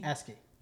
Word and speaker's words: ASCII: Or ASCII. How's that pronounced? – Yeah, ASCII? – ASCII. – That's ASCII? Fine.